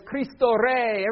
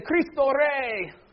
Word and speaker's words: Cristo Rey: And the rallying cry is, Cristo Rey. Everybody, if you can roll your R's, you have to roll your R's, or it doesn't count. Say, Cristo Rey.